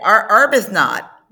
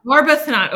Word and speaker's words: Arbuthnot: Arbuthnot. – Arbuthnot.